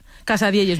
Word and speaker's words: Casadielles: ¿Lo digo bien? – Casadielles, muy bien dicho.